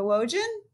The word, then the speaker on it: Wojan: Pull down my name, Barbara Wojan, rhymes with Trojan, and please support me.